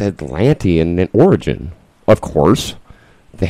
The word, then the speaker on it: Atlantean: Atlantean.